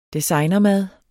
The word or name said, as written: designermad